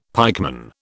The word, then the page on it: pikeman: British English